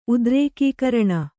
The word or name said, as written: ಉದ್ರೇಕೀಕರಣ